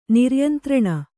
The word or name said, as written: ನಿರ್ಯಂತ್ರಣ